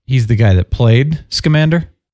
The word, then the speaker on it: Scamander: Because whenever I read it, I like don't know how to pronounce the last name, but it's Salamander. – Newt Scamander. – Yeah, that, yeah. Scamander, yeah, yeah. I know, it's like Scamander.